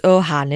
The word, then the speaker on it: Ogham: It's Ohan, isn't it? It's a silent G.